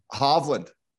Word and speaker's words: Hovland: How do we say his name? – Hovland.